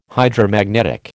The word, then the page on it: Hydromagnetic: American English